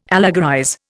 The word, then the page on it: allegorize: Indian